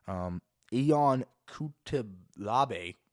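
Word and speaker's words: Ion Cutelaba: Ion Kutalabe, that's exactly how you say it.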